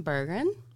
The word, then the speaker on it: Bergren: So today we're speaking to Sam Bergen. Is that how you say it? – Yeah. Perfect. Great. First time.